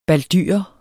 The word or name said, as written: baldyre